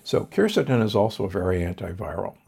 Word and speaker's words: quercetin: I'm a big fan of quercetin, sometimes pronounced quercetin.